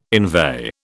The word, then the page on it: inveigh: British English